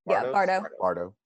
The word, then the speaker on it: Bardo: Bardo.